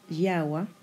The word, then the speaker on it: Yahweh: La versión de Yahweh usa el nombre YHWH, no se decía en voz alta, pero se pronunciaría Yahweh y es un dios antropomórfico que toma forma humana.